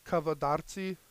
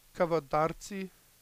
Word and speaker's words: Kavadarci: Say that slower, please. – I'll say it like Kavadarci. – Kavadarci? – Kavadarci.